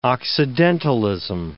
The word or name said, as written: Occidentalism